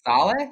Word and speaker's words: Saleh: Saleh.